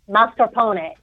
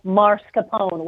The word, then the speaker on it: mascarpone: And so many people have transposed the R and they call it mascarpone. There is no R in the first syllable.